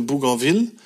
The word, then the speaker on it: Bougainville: Wie spricht man es aus? Deswegen habe ich es so ausgesprochen, weil es ist benannt nach Louis Antoine de Bougainville.